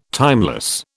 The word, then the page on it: timeless: British English